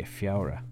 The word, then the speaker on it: Feabhra: Play a reel called Fiaura. It's a composition of Pedro Riadas. You know, it's spelled strange. It's actually the word for February, so it's February. And in Irish, it's spelled F-E-A-B-H-R-A, Fiaura.